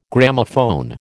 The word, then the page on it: Gramophone: American English